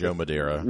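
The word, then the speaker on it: Madureira: Art by Joe Madera. I can't say his name correctly. – Joe Madera. – Madera? Okay. – I think you said it.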